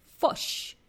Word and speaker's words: fish: and then I noticed they also pronounce the word fish, the fish, the animal that swims in the sea, F-I-S-H, fish sounds like fush, okay? Sounds like fush, like really, like hard O.